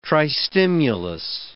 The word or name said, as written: tristimulus